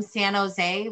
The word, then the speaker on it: San Jose: We're very excited because we realized that where we're from, San Jose rhymes with Fenway.